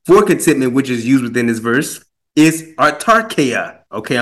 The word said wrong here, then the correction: autarkeia: It's autarkia, and autarkia is spelled A U T A R K E I A.